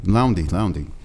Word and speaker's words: Loundy: Hey, David, how do you say your last name? Laurie? – Loundy.